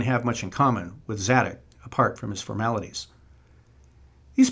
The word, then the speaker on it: Zadok: Zadok is how we can pronounce it,